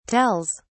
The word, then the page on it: tells: British English